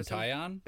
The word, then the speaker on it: Taillon: Jameson Taillon? – That's it.